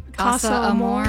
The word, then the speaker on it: Casa Amor: Season 8's Casa Amor. How do I pronounce that? – Casa Amor.